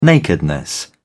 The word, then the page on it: nakedness: British English